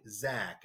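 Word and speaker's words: Zak: At Get Back Zach, and that Zach with a Z-A-K,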